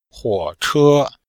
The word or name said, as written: huo3che1